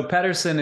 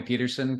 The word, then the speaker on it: Pedersen: So Pedersen is the Danish way to say it, but people call me Pedersen because it's just, you know, living where I have for so long, that's just become part of, just part of the vernacular.